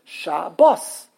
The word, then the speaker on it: Shabbos: Where do you put the emphasis on a Hebrew word? – Right. – On the last syllable. Right. It's not the last letter. It's called the last syllable because the last syllable can be even three letters sometimes. The last syllable is bos. So it's shabos. Good shabos. Good G-O-D-D-K in English.